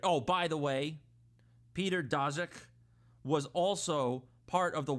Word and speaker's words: Daszak: This is an email from who I told you, Dr. Peter Daszak. I couldn't pronounce his name the first time. Daszak, president of EcoHealth Alliance.